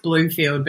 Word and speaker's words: Blomfield: It's pronounced Bloomfield, but it's spelt with one O, so it's confusing.